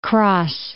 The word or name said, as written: Kras